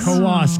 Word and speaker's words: Coos: It's up by Canada, but it's Coos County. Yeah, two syllables. – Coos County.